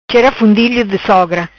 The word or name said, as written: Chera Fundilho Di Sogra